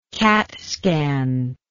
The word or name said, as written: CAT scan